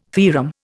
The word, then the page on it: theorem: Indian